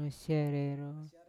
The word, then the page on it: masiarero: Yawa